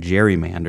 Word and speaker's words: gerrymander: Somewhere along the way, we changed the hard G to a soft G and started using the term gerrymander